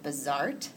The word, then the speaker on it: Bazaart: It was called I think you pronounce it Bazart. B-A-Z-A-A-R-T.